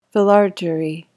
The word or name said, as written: philargyry